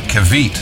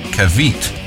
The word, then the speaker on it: Caveat: Occam's Razor, Trauma Concept, In Silence, Labor, and I think the last band of the day is called Kavit, C-A-V-E-A-T, Kavit. I think that's how you pronounce it.